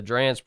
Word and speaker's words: Drane: So there was a family whose last name was Dran, spelled D-R-A-N-E, but they pronounced it Dran.